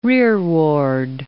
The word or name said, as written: rearward